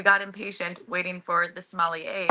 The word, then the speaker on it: sommelier: Sommelier. Is that how you say it? – You said it right. Sommelier.